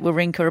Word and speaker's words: Wawrinka: Yes, I'm pronouncing it Wawrinka. Apparently, we've been pronouncing his name wrong for the past 15 years.